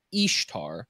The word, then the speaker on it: Ishtar: And on this thing, what it says is that the name Easter comes from the name Ishtar, which would technically be pronounced like correctly Ishtar, but they say, oh, you say it exactly the same way. Her name is Easter. That's how you say Ishtar.